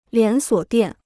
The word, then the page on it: 连锁店: lián suǒ diàn